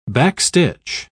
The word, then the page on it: backstitch: British English